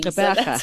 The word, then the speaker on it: Gqeberha: Gqeberha.